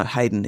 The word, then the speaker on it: Heydon: No, Hayden is a village in Norfolk, and it's spelled that way, H-E-Y-D-O-N, yeah.